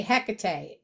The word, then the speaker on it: Hecate: So is that, I would say Hecate. Is that the same goddess? – Yes. Yes, my friend is the high priestess of an Hecate temple, and that's what she calls it. That's the correct Greek pronunciation.